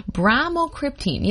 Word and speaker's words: bromocriptine: That's bromocriptine.